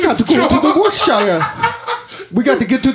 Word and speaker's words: Worcestershire: How'd you just say it? You just said it wrong. – Worcestershire. Worcestershire. – Worcestershire. – Sounds like a fucking word. Goddamn. – It's called Worcestershire.